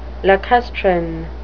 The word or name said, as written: lacustrine